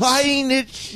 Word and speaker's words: Voynich: Voynich. – That's how it's pronounced.